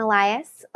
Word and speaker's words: Elias: It's Jasmine Elias.